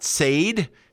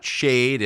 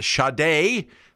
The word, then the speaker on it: Sade: Up next, we have an artist whose name is spelled nothing like it's pronounced, S-A-D-E. It's not Sade. It's not Shade. It's Sade. Say it with me, everyone.